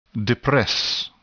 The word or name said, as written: depress